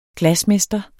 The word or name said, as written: glasmester